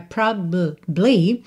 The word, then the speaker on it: probably: Olha ela aqui de novo, a palavra probably, a palavra de três sílabas que só soam duas. Às vezes, né? Às vezes a gente até pode ter probably, tá? Mas, frequentemente, especialmente em letra de música, quando você tem pouco tempo ali, esse probably, essa sílaba do meio, probably, que não é ba, né? Esse A não aparece. Então, é probably.